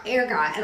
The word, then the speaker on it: ergot: I think it's argot. – Ergot. – Okay. Ergot. How it's pronounced.